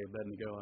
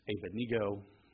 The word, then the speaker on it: Abednego: When I say Abednego, I look at my wife, she's got a smile on her face. She says, "That's not how you say it. It's Abednego."